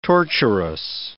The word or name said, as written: torturous